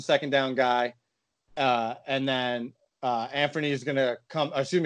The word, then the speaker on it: Anfernee: First of all, Anfernee is how you say his name. Like, seems like nobody knows how to say it.